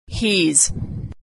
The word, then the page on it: hes: z